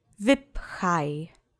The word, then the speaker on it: wypchaj: Accent is on the first syllable. Let's say wypchaj together. Wypchaj.